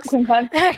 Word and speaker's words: quincunx: When you trine, your lover's four signs, and then quincunx, how do you say that? – Yep. – Quincunx? – Yeah, you quincunx? Said it, yep, quincunx.